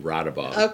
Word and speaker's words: Raudabaugh: Raudabaugh.